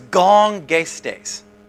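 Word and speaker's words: gongestes (γογγυστής): The Greek word is gongestes. Gongestes. That's how you say it.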